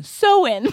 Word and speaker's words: Samhain: S-A-M-H-A-I-N. – It looks like Sam Hain. – Yeah. – But Sam Hain. Right? – I feel like it's not. I feel it's like it's like Samane. – Sowin. – It's Sowin? – Sowin. Right here it says pronounce Sowin or Sowin, maybe.